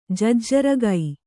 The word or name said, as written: ಜಜ್ಜರಗೈ